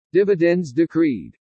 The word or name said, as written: DIVIDENDS DECREED